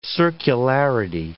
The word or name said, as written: circularity